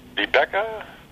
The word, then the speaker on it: Vibeke: Most people have a hard time pronouncing that first name, Vibeke. We've heard Vibeke. We've heard Vibeke. Vibeke. But it's Vibeke.